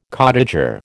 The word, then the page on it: cottager: American English